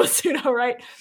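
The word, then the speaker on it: Sudowrite: who is the founder of something called Pseudo Write. It's spelled S-U-D-O, Write. But obviously it sounds like P-S-E-U-D-O, Pseudo Write.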